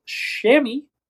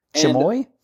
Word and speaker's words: chamois: And the spelling of this word, CHAMOIS. The pronunciation would be chamois. – Chamoy? – Chamois?